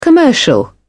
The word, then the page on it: commercial: British English